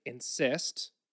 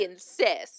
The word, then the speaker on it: encyst: They travel to striated muscles where they insist. – They insist. – It's spelled E-N-C-Y-S-T. This is another thing, like seeing it written, I'm like, oh, it makes sense. But saying it out loud.